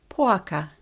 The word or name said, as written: Poaka